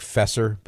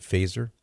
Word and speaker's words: Feser: F-E-S-E-R. It looks like Fesser, but he pronounces it Faser, and he co-authored that with Joseph Bissett.